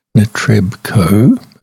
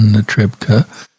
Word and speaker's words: Netrebko: Our piece of vocal music to start off today features Anna Netrebko, pronounced in English, I guess, Netrebko, but in Russian, Netrebko.